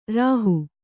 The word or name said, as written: ರುಹೆ